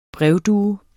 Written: brevdue

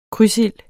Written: krydsild

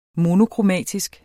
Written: monokromatisk

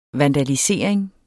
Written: vandalisering

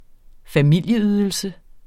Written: familieydelse

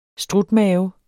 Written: strutmave

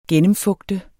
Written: gennemfugte

